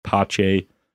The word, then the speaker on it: Pacé: My last name is spelled like Pace. Very confusing to people. I was thinking recently maybe I should change it to spell it like phonetically. – Pache, because Rudy Pace does not really have the ring that Rudy Pacé does to it, I don't think.